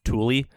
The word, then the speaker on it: Thule: Apparently it's pronounced Thule. – I thought it was Thule too. Okay. – It looks like right, Thule. Fine. It rhymes with mule. Everyone's like, oh, hey, it's pronounced Thule. I was like, oh, come on. It's spelled like mule.